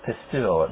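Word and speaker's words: pisteuo: and my Greek students could tell you that that's pronounced pistuo.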